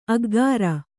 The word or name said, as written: ಅಗ್ಗಾರ